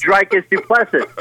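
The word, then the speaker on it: Dricus du Plessis: And the first name that we have to learn is Dricus Duplessis. It's a very, very French sounding pronunciation. – I, I have you look at his name, Duplessis. you think he's Dricus Duplessis.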